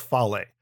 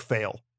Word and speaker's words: Fale: The Machine Gun Carl Anderson, Tama Tonga, and Bad Luck Fale. And I had to make a note right here. I'm going to let everybody know. Pronounce it Fale because I've always said in my head, Bad Luck Fail.